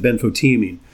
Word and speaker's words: benfotiamine: You pronounce it benfotiamine.